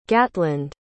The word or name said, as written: gatland